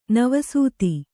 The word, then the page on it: ನಿವಸತ: nivasata